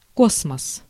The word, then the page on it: космос: /ˈkosməs/; kosmos